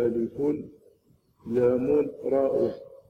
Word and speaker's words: الر: قال الله تعالى ألف لام را، ألف لام را، يعني هذه الكلمة الشريفة مركبة من ثلاثة حروف، ولهذا تنطق هكذا، كل حرف ينطق باسمه، يقال ألف لام راء،